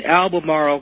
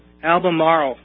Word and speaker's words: Albemarle: And that is in Albemarle County, Texas. – I probably mispronounced that, butchered it. – Albemarle. – Marl, okay.